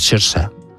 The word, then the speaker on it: Saoirse: que como es gaélico es Saoirse, es como se pronuncia.